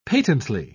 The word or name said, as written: patently